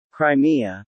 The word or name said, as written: Crimea